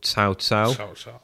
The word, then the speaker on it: Cao Cao: Yes, pronunciation looks like cow cow, but the C makes a TS sound. So Cao Cao was consolidating power in northern China.